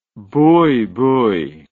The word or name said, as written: buibui